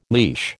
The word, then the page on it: leash: American English